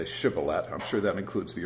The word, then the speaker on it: Shibboleth: So when he said no, then they would say to him, "Then say Shibboleth." And the Ephraimite would say Sibboleth, for he could not pronounce it correctly.